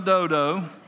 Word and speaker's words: Dodo: We're going to use the long O on that, all right, instead of doo-doo. All right, so the son of Dodo,